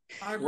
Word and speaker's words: Arbery: goodness yes arbery right arbery.